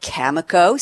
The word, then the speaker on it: Cameco: He is at Cameco, C-A-M-E-C-O.